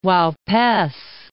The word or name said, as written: Uaupes